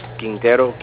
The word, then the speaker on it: Quintero: R-E-N-E. – Renee, perfect. And last name? – Quintero, Q-U-I-N-T-E-R-O. – T-R-O.